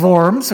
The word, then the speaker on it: Worms: But it actually is pronounced Worms, okay? Hans could help us with that, but it's formed.